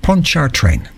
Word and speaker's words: Pontchartrain: I knew I was going to get it wrong when I said it the first time. Pontchartrain, that's how you pronounce it.